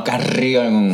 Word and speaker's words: Carrión: Which is how you say her name, not as I introduced her at Roscoe's, April Carrión. – No, Carrión.